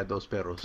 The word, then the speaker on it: Dos Perros: This is Yazoo Brewing Company's Dos Perros. Probably saying it wrong. It's like two dogs, right? – Yeah, Dos Perros. – Dos Perros.